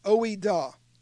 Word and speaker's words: oida: He says, but then indeed, when you did not know God, that is oina, that's what I want to say. Let me look at it again, oida. It's spelled like this, O-I-D-A. Do your own word search if you want to be like the Bereans and double check me here. OIDA is a Greek word.